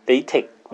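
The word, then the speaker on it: LaTeX: 이걸 라이텍스 라텍스라고 레이 뭐 이렇게 안 읽고 레이텍 뭐 이렇게 뭐 더 정확히는 뭐 흐 발음이 끝에 나야 된다는 말도 있는데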